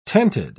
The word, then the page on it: tented: British English